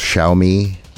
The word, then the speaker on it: Xiaomi: I don't exactly know how to pronounce it, but I want to say it's pronounced Xiaomi. – Sounds about right.